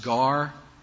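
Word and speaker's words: gar (γάρ): That is the word "gar." It's pronounced "gar" in Greek.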